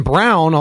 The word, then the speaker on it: Braun: All these guys, hell, you got rookie Christian Brown, although it's spelled Braun, it kind of bothers me.